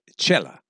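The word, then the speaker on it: Xela: I thought of Chela, Guatemala, that's X-E-L-A, pronounced Chela.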